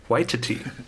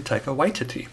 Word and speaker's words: Waititi: Wait, and I recently heard how he actually pronounces it. Waititi. Taika Waititi. Because I would say Taika Waititi. But it's apparently Taika Waititi. You learn something new every day.